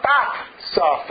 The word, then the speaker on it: FAFSA: Did you fill out a FAFSA form? Yeah? Did they ask how much was, how do you say it? – FAFSA. – I thought it was FAFSA. It's F-A-F. – F-A-F-A. – No, it's F-A-F-S-A.